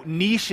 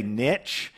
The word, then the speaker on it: niche: And you know, niche is actually how you say it. But here in America, we say niche. If you've ever heard marketers say the riches are in the niches, you know, it kind of rhymes.